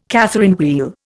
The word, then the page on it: Catherine wheel: Indian